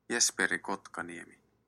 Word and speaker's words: Jesperi Kotkaniemi: Jesperi Kotkaniemi.